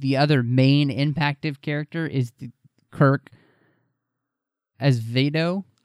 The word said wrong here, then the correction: Acevedo: I think is how he would say his name. Acevedo, excuse me. – Acevedo.